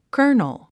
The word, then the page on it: colonel: kə́ːrnl; カーネル